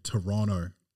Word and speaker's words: Toronto: They say Toronto up there. They don't like you to pronounce that second T.